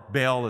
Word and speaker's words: Baal: Dagon is the god of fertility and grain, father of Baal, or Baal as some pronounce, and he's head of the Philistine crew of gods.